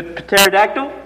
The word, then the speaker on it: pterodactyl: Uh, p- pterodactyl? – No, and the P is silent.